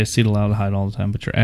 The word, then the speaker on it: acetaldehyde: So it probably hits all the categories you want, and maybe dock it just a bit for the acetaldehyde. Crap, I said it wrong. No, I didn't. – Yeah, you said it right. Acetaldehyde.